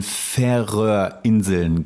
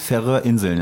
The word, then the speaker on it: Färöerinseln: zu den Färöerinseln gesegelt. Habe ich das richtig ausgesprochen? Richtig. – wie sagt man das? Färöerinseln, ja?